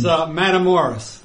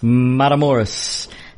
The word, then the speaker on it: Matamoros: And everybody mispronounced it. It's Matamoros. – Matamoros.